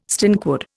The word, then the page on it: stinkwood: Indian